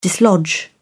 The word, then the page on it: dislodge: British English